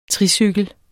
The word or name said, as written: tricykel